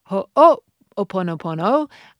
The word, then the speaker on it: ho'oponopono: So our Hawaiian forgiveness practice of ho'oponopono.